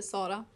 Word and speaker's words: Sara: Am I saying it right? Sara or Sara? – Say however you want to. I think everyone here says it differently. – Yeah. – But on Swedish, you would say Sara.